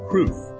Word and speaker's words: crwth: Words like C-R-W-T-H, pronounced proof. It is a Welsh stringed instrument, and C-W-T-C-H, it can mean either a hiding place or a gentle hug, and it's pronounced cooch.